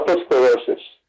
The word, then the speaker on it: otosclerosis: Tell me about what you uh, otosclerosis. Am I saying that right? Oh yeah, otosclerosis.